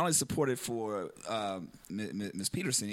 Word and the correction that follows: Patterson: here, but did I say your name wrong? – Patterson. – I said Patterson the first time, too, I think, too.